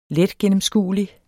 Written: letgennemskuelig

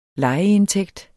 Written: lejeindtægt

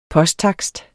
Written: posttakst

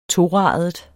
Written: TORADET

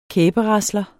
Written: kæberasler

